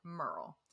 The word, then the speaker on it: Muriel: The pronunciation, it is spelled M-U-R-I-E-L. But when he started interviewing people, he realized that they more pronounce it like Merle.